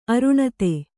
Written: ಅರುಣತೆ

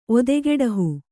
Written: ಒದೆಗೆಡಹು

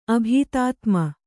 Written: ಅಭೀತಾತ್ಮ

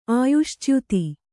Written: ಆಯುಶ್ಚ್ಯುತಿ

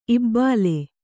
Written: ಇಬ್ಬಳ

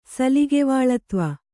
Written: ಸಲಿಗೆವಾಳತ್ವ

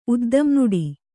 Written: ಉದ್ದಂನುಡಿ

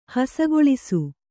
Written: ಹಸಗೊಳಿಸು

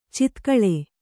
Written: ಚಿತ್ಕಳೆ

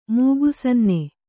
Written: ಮೂಗುಸನ್ನೆ

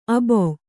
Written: ಅಬಾ

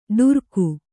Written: ಡರಿಕೆ

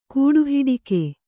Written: ಕೂಡುಹಿಡಿಕೆ

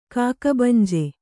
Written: ಕಾಗೆಬಂಜೆ

